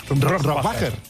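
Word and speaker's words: Rohrwacher: Bueno, ¿cómo se pronuncia Alba Rohrbacher-Kochet? – Rohrbacher. – Rohrbacher. Sí.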